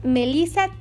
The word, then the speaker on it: Melissa: Me vas a encontrar con mi nombre, Melisa Tejeda.